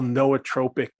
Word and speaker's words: nootropic: I know for many, many years, my background has been in, or my business has been in, creating what they call nootropic formulas. – Oh, I said it wrong. Sorry. Okay. – No, you did great.